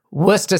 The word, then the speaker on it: Worcester: There's something, and I don't really know why, but our shows at the Worcester Centrum, pronounced with a locals' accent, would be Worcester Centrum.